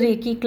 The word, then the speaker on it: Reiki: to the Reiki class. Reiki is pronounced as Rei Ki. It's a Japanese word, universal life force energy.